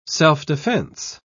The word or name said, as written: self-defense